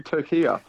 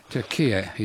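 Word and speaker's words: Türkiye: Turkiya. – Turkiya is correct.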